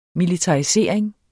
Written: militarisering

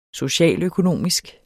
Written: socialøkonomisk

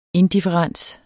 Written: indifferens

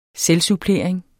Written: selvsupplering

